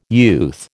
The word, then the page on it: youth: American English